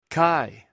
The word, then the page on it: kai: Greek